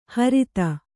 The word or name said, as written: ಹ್ರೀತ